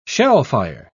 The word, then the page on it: shellfire: British English